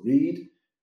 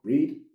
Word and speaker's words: read: Para mí, un truco que uso con mis estudiantes es pensar en el color rojo, porque es red. Red. So, read, rojo. Read, red. Read, red. – Vale, o sea que leer es read, pero en pasado es read, aunque se escribe igual.